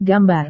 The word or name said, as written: gambar